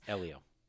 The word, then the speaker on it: Helio: Now, in contrast, I will say this I didn't get a picture or talk to Helio Castroneves. Elio. Elio. Okay. – I'm H not, is silent.